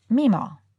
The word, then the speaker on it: Meemaw: So it is Meemaw. Rhymes with hee-haw.